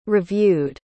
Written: reviewed

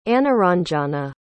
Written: anuranjana

